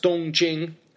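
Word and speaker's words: Dongjing (东京): And if you look at the kanji for the city of Tokyo, the Japanese pronounce it Tokyo, but in Mandarin, these characters are pronounced Dongjing, first tone, Dong means east, so Dongjing means eastern capital, which the Japanese pronounce Tokyo.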